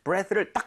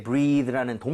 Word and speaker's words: breath: 그리고 이게 breathe라는 동사가 아니에요. 그죠? 숨이라는 명사란 말이에요. 그래서 발음할 때 breathe 이게 아니라 breath.